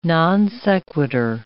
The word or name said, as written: non sequitur